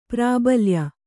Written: ಪ್ರಾಬಲ್ಯ

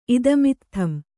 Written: ಇದಮಿತ್ಥಂ

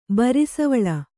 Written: ಬರಿಸವಳ

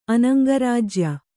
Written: ಅನಂಗರಾಜ್ಯ

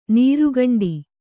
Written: ನೀರ್ಗುಂಡಿ

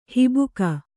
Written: ಹಬ್ಬುಗೆ

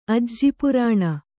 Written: ಅಜ್ಜಿಪುರಾಣ

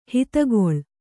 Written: ಹಿತಗೊಳ್

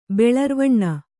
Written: ಬೆಳರ್ವಣ್ಣ

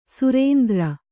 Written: ಸುರೇಂದ್ರ

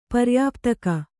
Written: ಪರ್ಯಾಪ್ತಕ

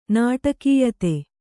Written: ನಾಟಕೀಯತೆ

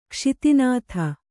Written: ಖಾಸತೈನಾತಿ

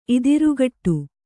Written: ಇದಿರುಗಟ್ಟು

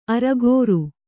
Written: ಅರಗುರಿ

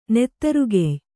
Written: ನೆತ್ತರುಗೆಯ್

